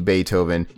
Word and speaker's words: Beethoven: It says Beethoven, and it's got the little puppy Beethoven.